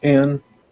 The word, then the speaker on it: Ayn Rand: Noted atheist author, and forgive me, is it pronounced Ayn Rand? Ayn Rand? – Ayn Rand.